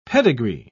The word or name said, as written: pedigree